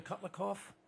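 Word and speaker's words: Kotlikoff: Dr. Kutlikoff, am I pronouncing that mm-hmm. correctly? Did I pronounce that correctly? – That's exactly right.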